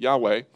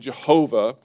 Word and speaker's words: Yahweh: And so the way that you spell Jehovah or Yahweh, which is the way I pronounce it, Yahweh, is Y-H-W-H. Those four letters, so it's called the Tetragrammaton. And some people pronounce that Jehovah.